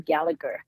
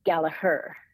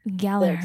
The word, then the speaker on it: Gallagher: So, well, actually, there's a little part in the book where they make fun of Americans for pronouncing their last name Gallagher. Uh-huh. And in Ireland, it's pronounced Gallagher. – Gallagher. – The second G is silent.